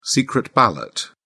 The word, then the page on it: secret ballot: British English